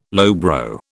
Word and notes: lowbrow: British English

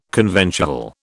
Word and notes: conventual: British English

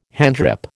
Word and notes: handgrip: American English

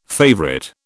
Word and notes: favourite: British English

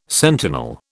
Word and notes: sentinel: British English

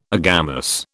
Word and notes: agamous: British English

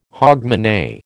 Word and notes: hogmanay: American English

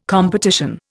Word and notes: competition: Indian

